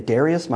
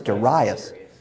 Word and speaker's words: Darius: I just saw that the other day and went, I've been saying Darius my whole life. Turns out it's Darius.